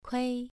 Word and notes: kui: 2

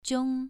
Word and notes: jiong: 2

